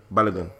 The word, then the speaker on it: Balogun: So what happened to Balogun? Is that how you pronounce his name? – Balogun.